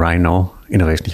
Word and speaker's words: Rhino Innovation: Patrick, erklär uns doch kurz, ähm, was steckt hinter Rhino Innovation. Ich hoffe, ich habe es richtig ausgesprochen. Völlig korrekt ausgesprochen.